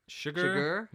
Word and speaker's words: Chigurh: Chigurh. I think it's actually meant to be pronounced like sugar.